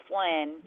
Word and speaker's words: Flann: Descendant of Flann. F-L-A-N-N. – All right. – So it's an Irish name. Gender masculine, uses English rare, pronounced Flynn.